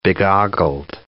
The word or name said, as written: begoggled